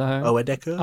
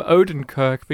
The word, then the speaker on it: Oedekerk: It's Steve Oedekerk. – I think so. – Oedekerk. Oh, I don't want to call him Odenkirk because that's a different comedian.